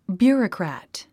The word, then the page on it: bureaucrat: bjú ə rəkræ`t; ビューロゥクラット